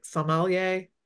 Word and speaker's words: sommelier: Am I saying that right? Sommelier? – That's perfect.